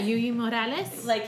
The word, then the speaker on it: Yuyi Morales: Oh, Yuyi Morales.